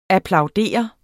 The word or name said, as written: applaudere